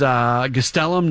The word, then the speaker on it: Gastelum: We've gone back and forth a little bit on what the pronunciation is, by the way. And it does sound like it is Gastelum, not Gastelum, as we were initially, as the initial indication was.